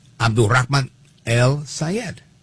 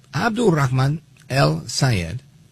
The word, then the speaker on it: Abdulrahman El Sayed: Most recently, Abdulrahman El Sayed, right? And I say it like that again, at his request. So, Abdulrahman El Sayed, he says he's not moderating his position on Israel.